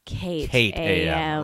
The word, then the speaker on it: JackAM: And if you're a real fan, you'll know that JackAM may be spelled JackAM, but it's pronounced KDAM.